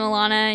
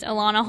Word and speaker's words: Elana: I'm Elana, and you can still find me online. probably just Elana Holder, and it's spelled weird. It's E-L-A-N-A, not A-L-A-N-A, or Elena, or Elma.